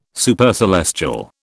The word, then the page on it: Supercelestial: British English